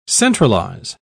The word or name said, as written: centralize